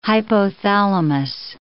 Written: hypothalamus

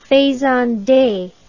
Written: faisande

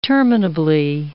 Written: terminably

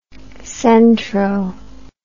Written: centro

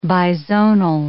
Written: bizonal

